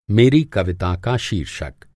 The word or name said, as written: शीर्षक